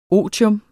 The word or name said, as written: otium